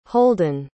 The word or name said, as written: holden